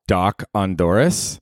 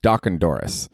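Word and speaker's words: dochandorris: Dude, my word of the day on Saturday was Doc Ondoris, which is Scottish and Irish, and it means a parting drink. It's spelled D-O-C-H-A-N-Doris, D-O-R-R-I-S, Doc Ondoris.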